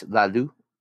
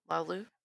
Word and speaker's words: Laloux: I'm not sure if I said that right. Does that look right to you in French? Laloux? L-A-L-O-U-X?